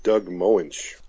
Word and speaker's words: Doug Moench: Doug Moen? I can't pronounce that, sorry. – You're failing me everywhere. – I'm sorry. Larry Hama was the artist. Dick Giordano was the inker again. Letterer is Artie Simic, and P. Goldberg is the colorist, and Roy Thomas was still the editor. Maybe you can pronounce the writer. – I'm going to go with Doug Moench.